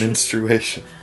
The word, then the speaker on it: menstruation: It's menstruation. A period piece. Come on, Kevin, you're quicker than that. – I've just never heard the U pronounced before.